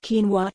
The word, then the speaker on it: quinoa: Quinoa, pronounced quinoa, tastes very similar to brown rice and has a similar look and consistency as well.